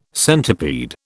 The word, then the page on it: centipede: British English